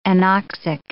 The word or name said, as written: anoxic